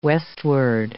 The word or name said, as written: westward